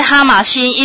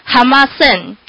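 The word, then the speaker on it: 浜線: 啊，伫这个所在啊，其实这个哈马新，伊都是日本人称这两条铁路称为滨线啊，日语发音都是哈马线啊。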